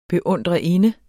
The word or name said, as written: beundrerinde